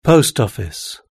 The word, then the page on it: post office: British English